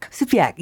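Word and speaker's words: Sugpiaq: I'm going to pronounce this wrong. Is a native Alaskan, she's not Yupik. She's Supiak. – Supiak, yes, that's okay.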